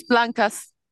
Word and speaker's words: Blancas: It's Blancas.